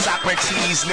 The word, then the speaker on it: Socrates: I am on the run and it's pronounced Socrates.